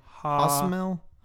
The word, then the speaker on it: Josmel: It's J-O-S. I'm sure it's pronounced like an H, right? Hosmel? Hosmel? – Ishmael? – Hosmel? No, it's not Ishmael. J-O-S-M-E-L.